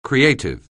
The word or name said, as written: creative